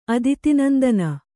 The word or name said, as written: ಅದಿತಿನಂದನ